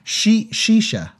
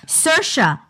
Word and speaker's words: Saoirse: Shisha. – No, it's pronounced... – Not Shisha. – Saoirse. Not... – You're so... Oh my God, you've had too much beer. Saoirse, like inertia.